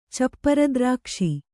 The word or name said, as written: ಚಪ್ಪರದ್ರಾಕ್ಷೆ